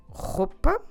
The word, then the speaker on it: roupa: We say a roupa. Como se escreve roupa? R-O-U-P-A.